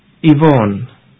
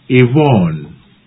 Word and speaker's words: Yvonne: Y-V-O-N-N-E. – Yvonne. – Now, here's another example of appearances being deceptive. Although the name begins with a Y, we do not say Yvonne.